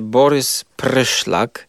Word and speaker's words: Borys Pryszlak: On poznał kiedyś Polaka, tak uwaga, polski akcent, Polaka, który nazywał się Borys Pryszlak.